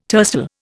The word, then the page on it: tercel: Indian